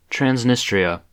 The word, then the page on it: Transnistria: /tɹænzˈnɪsˌtɹi.ə/